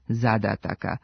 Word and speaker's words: zadataka: Ovoga puta nismo čuli ni jedan njen pogrešan oblik, ali je toliko puta pomenuta da bi trebalo reći kako je u genitivu množine jedino ispravan akcenat ove imenice zadataka, sa kratko uzlaznim akcentom na prvom slogu i dužinama na svakom sledećem.